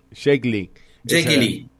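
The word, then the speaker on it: Jake E. Lee: Jack Ely, ¿no? Sería más o menos. – Sí, Jake Ely. – Esa era la pronunciación.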